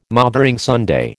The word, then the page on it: Mothering Sunday: American English